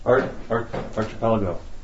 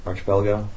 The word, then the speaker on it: archipelago: Archipelago. – Archipelago.